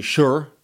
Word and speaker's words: shire: But the one thing I got was several people telling me the pronouncer, how to pronounce S-H-I-R-E. And I got both that it should be basically sure or even less than that.